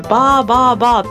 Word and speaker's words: 333: ベトナムビールでこれ 333 って書いてバーバーバーって読むんだけど、バーバーバーバーバーバーみたいな。